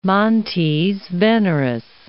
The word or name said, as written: montes veneris